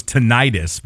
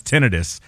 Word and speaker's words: tinnitus: I thought it was tinnitus, but now it's tinnitus.